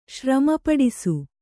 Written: ಶ್ರಮಪಡಿಸು

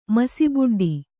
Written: ಮಸಿಬುಡ್ಡಿ

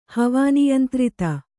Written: ಹವಾನಿಯಂತ್ರಿತ

